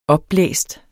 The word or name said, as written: opblæst